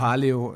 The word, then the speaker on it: Paleo: Paleo ist eigentlich so die ursprüngliche Ausdrucksweise.